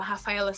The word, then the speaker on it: Raphaela: do meu Instagram é arroba Rafaela Cítico, a Rafaela com PH, Cítico S-I-T-K-O, meu nome não é muito fácil.